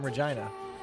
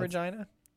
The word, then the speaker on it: Regina: Have you ever been to Regina? – No, I've never even been to Saskatchewan. – Me neither. But I've heard their capital is the city that rhymes with fun.